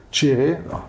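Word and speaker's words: Kyereh: Kire, oder das Kire, wie er ausgesprochen wird. Chiré. – Chiré.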